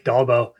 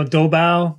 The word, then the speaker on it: Dolbo: That's, you know, Dolbo, you know, there's all kinds of different pronunciations, but it's Swedish, so it rhymes with Volvo.